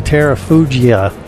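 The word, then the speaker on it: Terrafugia: I am your co-pilot here on Just Plain Radio, and I'm standing next to the Terra Fugia.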